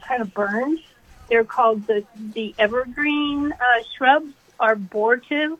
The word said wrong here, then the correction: arborvitae: I'm saying that wrong. – Arborvitae? – Arborvitae. – Yeah. Arborvitae, yeah.